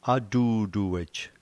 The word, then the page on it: atutuej: slow